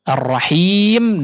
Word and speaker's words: Ar-Rahim: Ha di situ juga yang ada di Ar-Rahim perlu diperhatikan ya cara pengucapannya, yang jelas atau dihaluskan ya, tidak condong kepada kha ataupun kepada huruf ha mungkin ya.